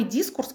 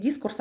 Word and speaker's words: дискурс: Кстати, тот самый дискурс, которым я занимаюсь, э, занималась, а, можно говорить дискурс, а можно говорить дискурс. И там есть эм, признак, э, лингвисты говорят дискурс, а литературоведы говорят дискурс.